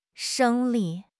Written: 生理

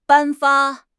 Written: 颁发

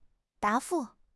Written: 答复

